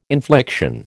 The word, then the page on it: infliction: American English